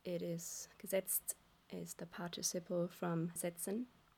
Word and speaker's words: Gesetzt: Like you really need to pronounce this last T, like walk the last mile and pronounce it. So don't be lazy. – Yeah. – Pronounce the last T to really get the TZT out with Gesetzt.